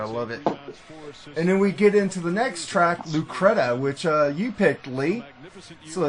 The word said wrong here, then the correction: Lucretia: here's, and it's called Lucretia. – Lucretia. Okay, I said it wrong.